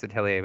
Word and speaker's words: Atelier: See, I know it's pronounced Atelier, but I feel weird. I say Atelier. – I know. – Anyway. – I'm not French. The R is not silent in my brain, which that's very uncultured of me, but whatever.